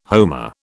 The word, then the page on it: homer: British English